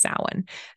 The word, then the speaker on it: Samhain: Um, so that's it, it, looks like it's spelled Samhain, but it's pronounced Samhain.